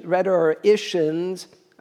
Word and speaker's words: rhetoricians: Rhetoricians, I keep mispronouncing that word. There is no T. There's no second T.